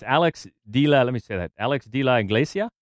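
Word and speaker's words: Álex de la Iglesia: And it's Alex de la Iglesia.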